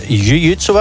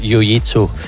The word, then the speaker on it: jiu-jitsu: Eigenlijk wordt het jiu-jitsu uitgesproken, maar in de meeste clubs in Vlaanderen zeggen ze toch wel jiu-jitsu.